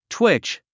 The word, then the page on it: twitch: twítʃ; トゥイッチ